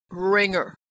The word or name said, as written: ringer